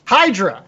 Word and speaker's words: Hydra: The Hadria? Or something? – Hydra. – Is that what how they pronounce it? – That's how I read it.